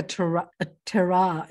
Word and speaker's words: terroir: Wow, so like a terroir, is that how you pronounce it?